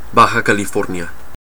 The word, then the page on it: Baja California: /ˈbɑː.hɑː.kælɪˌfɔː(ɹ)n.ɪə/